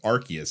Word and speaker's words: Arceus: Carrie, you talked last time you were on about Pokemon Legends Arceus, which Nintendo pronounces as Pokemon Legends Arceus.